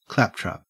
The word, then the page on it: claptrap: Australian English